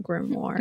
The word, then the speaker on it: grimoire: It's like O-I-R-E or something. – Yes. Something. And, but you do pronounce it at least in the American pronunciation. It's like grim and war. So grimoire.